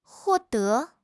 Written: huo4 de2

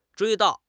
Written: 追悼

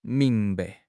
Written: ming2 bai5

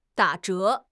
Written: da3 zhe2

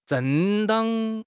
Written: 正当

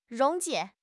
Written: 溶解

